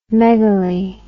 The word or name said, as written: megaly